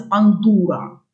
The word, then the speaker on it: пандура: И мы, в общем, следуем за классическим греческим, где в форме женского рода ударение ставится пандура.